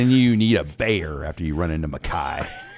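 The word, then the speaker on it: Gbayor: Did I say it right? – G silent. – Let's just keep the streak of simple names going here. – Makai Bayer. – Bayer. – Okay. – Like the aspirin.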